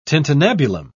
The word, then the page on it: tintinnabulum: British English